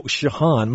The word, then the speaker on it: Sheehan: I'd like to introduce again the guest, Russell Sheehan. – It's actually Shahan. – Oh, Shahan. My apologies.